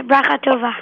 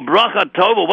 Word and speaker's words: Brachatova: Um, B-R-A-C-H-A-T-O-V-A. – B-R-A, Bracha, Bracha like that? – Yeah, Brachatova. – Oh, Brachatova!